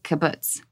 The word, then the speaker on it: kibbutz: If you don't mind, will you tell us a little bit about living in the kibbutz? – Oh, sure. – Am I saying that correctly? Kibbutz. – Yes, you are. Beautifully. Kibbutz.